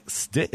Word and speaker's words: Stitt: Richards worked under the direction of Frank Stitt. Am I saying that correctly? – You are.